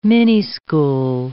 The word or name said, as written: minischool